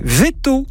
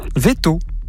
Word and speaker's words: Veytaux: Veto. Alors, ça s'écrit V-E-Y-T-A-U-X. – Veto.